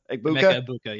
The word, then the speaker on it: Emeka Egbuka: Emeka Egbuka, yeah.